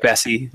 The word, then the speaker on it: Bessie: Is it Bessie, like Jesse? Yeah. – Like okay. Bessie, like the cow.